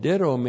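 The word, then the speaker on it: Didomi (δίδωμι): This is the articular aorist active participle of didomi me, plus the accusative singular of the intensive pronoun autos, plus the accusative singular spude with a definite article. Well, didomi, he's our old friend. D-I-D long O-M-I. Didomi.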